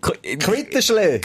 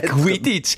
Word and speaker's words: Quidditch: Quittenschläge. Quitsch, irgendwie so, nein, Alter, Quidditch.